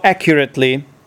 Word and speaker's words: accurately: I'm just wondering, like, where is the stress in the word accurate? Yeah, I think accuracy. I think it's on the first syllable. So how accurately, yeah?